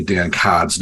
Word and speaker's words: Dan Codd: Now, I've been trying to teach Gavin how to say Dan Codd's name correctly. It's Dan Codd because he is from the Boston area, even though it's spelled card.